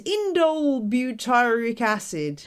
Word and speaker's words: indole butyric acid: is indole butyric acid or IBA.